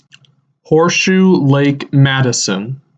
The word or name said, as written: Horseshoe Lake Madison